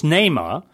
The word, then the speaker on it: Neymar: The other player who scored in that Brazil-USA game was, of course, Neymar. And what's the latest on him? Will he be? What did you smile at? I pronounced that completely wrong. – No, he's right.